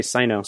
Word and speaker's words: Signo: He talked about it early on in the Pen Addict, but they talked about how the actual pronunciation is Signo, but they both just thought that sounded stupid, and so Brad just decided he was going to call it Sino.